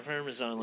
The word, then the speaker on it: Parmesan: How do you say it? Is it Parmesan? – Yeah, Parmesan, like, to powder stuff.